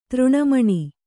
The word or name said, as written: ತೃಣಮಣಿ